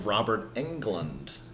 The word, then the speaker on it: Robert Englund: Robert Englund.